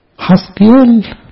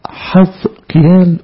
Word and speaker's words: حزقيال: الملحوظة التانية، معنى اسمه حزقيال ممكن تتنطق حزقيال وحزقيال، النطقين صح، عشان كده حتى تلاحظوا في الطابعة البيروتية الحاء عليها مادة وجر، يعني ممكن النطقين صح، حزقيال أو حزقيال. أنا بميل لحزقيال لأنه حزقيال هو في العبري نفس اسم حزقي، عشان بنقول حزقية فبقول حزقيال، لكن النطقين صح.